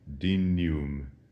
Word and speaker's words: dignum: Dignum would be classical Latin, but in ecclesiastical Latin, the GN is massaged a little bit, and we say it in the way that we would say in English, canyon, dignum.